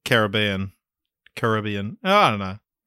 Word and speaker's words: Caribbean: And just off the bat, I'm going to be pronouncing it Caribbean, not Caribbean.